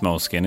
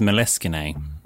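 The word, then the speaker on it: Moleskine: Do you know, it's not pronounced moleskin, it's meleskine.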